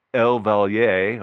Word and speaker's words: El Valle: And so in Taos here, or northern New Mexico, is we have the El Valier, or I've been told that it's pronounced two different ways, but the word is E-L-V-A-L-L-E, and it's Spanish for the valley.